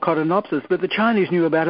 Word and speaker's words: codonopsis: And a thing called codonopsis, C-O-D-O-N-O-P-S-I-S, codonopsis.